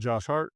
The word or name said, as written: Josh Hart